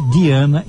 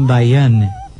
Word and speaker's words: Deanna: Atenção que o tempo todo eu disse Diana e não Diane. É porque a Diana Derby não se escreve como Diane. Ela se escreve D-E-A-N-N-A. Ela é Diana, como nós aqui no Brasil dizemos Diana e não Diane.